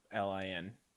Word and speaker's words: Kholin: Kholin.